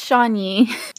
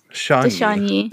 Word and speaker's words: Chagny: Is it Chagny? – It's Chagny. – Chagny. – That was pretty close. – Mm-hmm. Yeah. – Yeah, Raoul de Chagny.